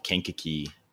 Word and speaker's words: Kankakee: So you grew up in just south of Chicago called Kankakee.